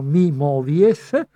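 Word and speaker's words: MYmovies: Si può fare un abbonamento, le informazioni le potete trovare appunto sulla piattaforma May Movies che si scrive M con la Y, Mi Movies, e Mi Movies One è il titolo e il, l'abbonamento costa 12,90 euro.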